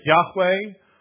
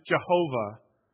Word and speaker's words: Yahweh: In your Hebrew Bibles, it would be YHWH. We sometimes pronounce it Yahweh, or in a Latinized version, we would pronounce it Jehovah.